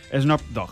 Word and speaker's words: Snoop Dogg: Snoop Dogg. ¿Se pronuncia así? – Snoop Dogg.